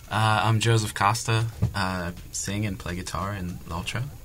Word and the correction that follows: L'Altra: In L'Altra. – Okay. – She actually pronounced the name of the band better than you, and shame on you about that.